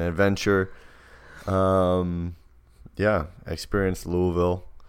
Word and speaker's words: Louisville: I need to pronounce none of these letters correctly to make this word, and I need to throw an extra L in there. Right. Louisville.